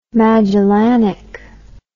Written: magellanic

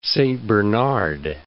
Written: Little Saint Bernard

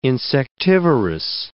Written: insectivorous

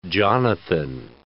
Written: Jonathan

